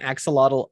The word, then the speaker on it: axolotl: I will say that the last character on my my stretch goal list is I'm going to say it wrong. A, a lox a lox a oh, uh, axolotl. Uh, axolotl. Axolotl. Axolotl.